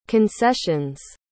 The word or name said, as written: concessions